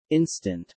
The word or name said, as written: instant